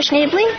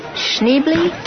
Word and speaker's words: Schneebly: Would you care to join us, Mr. Schneebly? – Yeah. – Did I say that correctly? Schneebly? – Actually, it's Schneebly.